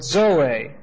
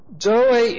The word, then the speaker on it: Zoe: Now the word for life here is the word Zoe. Z-O long E. Zoe is the word that's always used for eternal life in the New Testament.